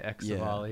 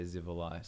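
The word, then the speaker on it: Xivilai: I'm just we called it Zivali when I was really young. Exivali was when I was a kid. I used to say Exivali. Yeah. But I believe I have heard an NPC in the Elder Scrolls Online say Zivalai.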